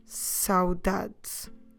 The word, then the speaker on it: saudade: So the idea of nostalgia or longing, so that feeling of missing someone is expressed by the noun saudade.